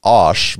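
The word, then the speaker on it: Æ: I'm told it's supposed to be pronounced Osh, but they're saying I or AI.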